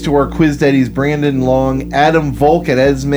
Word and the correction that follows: Esme: and I'm sorry, now I can start pronouncing it correctly because he pronounces it Esma. Okay. As in short for Esmeralda.